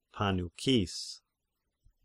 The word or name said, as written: Pannychis